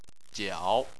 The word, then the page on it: jiao: 3